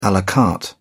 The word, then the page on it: a la carte: British English